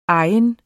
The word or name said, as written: egen